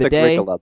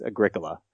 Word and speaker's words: Agricola: I think it's Agricola, by the way. Sorry. I think the Kronek, I've been saying it wrong most of my life, but it's Agricola.